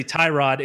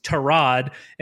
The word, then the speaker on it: Tyrod: Background, if people didn't see it, apparently Betonio, their left tackle, said that it's not actually Tyrod, it's Torod, and he's never actually corrected anybody, so we've been saying it wrong forever.